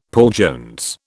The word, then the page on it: Paul Jones: British English